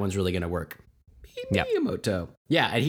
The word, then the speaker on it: Miyamoto: I believe his last name is pronounced Miyamoto. – There you go. – He's he created Mario, right? – Yeah. – Yeah. So just remember, it's a Miyamoto.